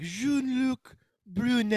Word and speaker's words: Jean-Luc Brunel: It's Jean-Luc Brunel. – Jean-Luc Brunel. – I know how to pronounce it, because earlier before the recording, I went through Google Translate, and the Google Translate was like Jean-Luc Brunel, and I'm like, oh, okay.